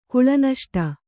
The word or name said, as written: ಕುಳನಷ್ಟ